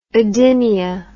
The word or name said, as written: odynia